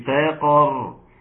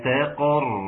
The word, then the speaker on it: سقر: سأصليه سقر. برضو رقق السين بتاع السقر ما تخليهاش سقر بالصاء.